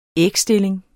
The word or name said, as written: ægstilling